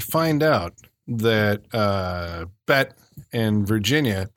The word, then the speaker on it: Virginia: Well, he has a thing for Bette, but it's like, what about poor Virginia there? – Virginia. Say it right, please. – Virginia. – Thank you.